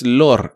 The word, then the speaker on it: Lore: Y es Lore, no Lord. – No, Lore. – Como en folklore.